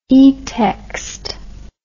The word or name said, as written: etext